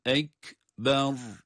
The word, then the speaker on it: ekber: El ekber. – Onun için bu kelimeyi okurken asla uzatarak okumuyoruz. Ra'yı da kalın okuyoruz.